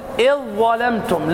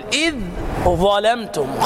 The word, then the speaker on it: إذ ظلمتم: وأيضا تقول إذ ظلمتم، لا تقل إذ ظلمتم خطأ إدغام، وعندك الحرف الثاني مشدد إذ ظلمتم.